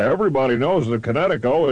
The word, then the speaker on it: Kinetico: Oh, on the East Coast, son, you should have remembered kinetic as in kinetic energy with an O on the end. Connecticut. – Connecticut. Spell it K-I-N-E-T-I-C-O.